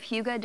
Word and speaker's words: hygge: There's a Danish word spelled H-Y-G-G-E, but pronounced hygge.